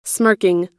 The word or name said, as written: smirking